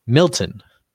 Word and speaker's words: Milton: Melton? Melton doesn't sound like a name, though. It sounds like I was melting it. Milton. I'm just pronouncing it wrong. That's what it is. It's Milton, not Melton.